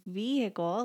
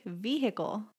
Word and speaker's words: vehicle: And he pointed out to Brandon that I say vehicle, like vehicle. I pronounce the H in it. Mm-hmm. And they're like, you don't pronounce, it's kind of like a silent H.